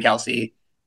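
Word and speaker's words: Kelce: I follow Jason and Kylie Kelsey. Did I say that right? – Yeah.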